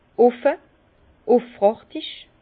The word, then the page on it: offe, üfrachtig: Bas-Rhin; Herrlisheim